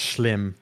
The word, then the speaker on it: Schim: There isn't, but that's how you pronounce it. It's Dutch. S C H I M is how you spell it, and it's pronounced Schlim.